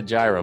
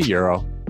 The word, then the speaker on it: gyro: The nice thing about that podcast is it corrects how people pronounce that word, because it's spelled gyro. And a lot of people are like, oh, yeah, I'm going to go get a gyro. But it's a euro.